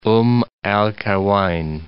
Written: Umm al-Qaiwain

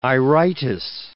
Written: iritis

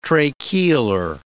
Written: tracheolar

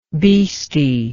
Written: bheesty

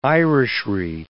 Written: Irishry

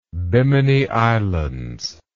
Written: bimini islands

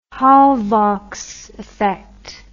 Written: Hallwachs effect

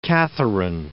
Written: Catherine

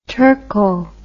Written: turko

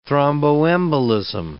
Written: thromboembolism

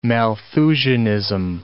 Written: Malthusianism

